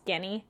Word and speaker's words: Genny: I think it's funny that they translate his name to, it looks like Genie, but it's Genny.